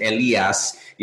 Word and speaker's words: Elias: So like even Elias, uh, you know, in Spanish, really my mom's the only one that pronounces it Elias. You know, but here in uh, the United States, it's it's a little challenging for for some folks to pronounce it that way.